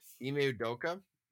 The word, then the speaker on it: Ime Udoka: Udoka? Boston Celtics head coach. I don't want to mispronounce his name. Ime Udoka? – Yeah.